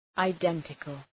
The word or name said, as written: identical